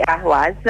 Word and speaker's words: Ahwaz: Di Ahwaz.